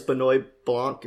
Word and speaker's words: Benoit Blanc: I think he plays Benoit Blanc.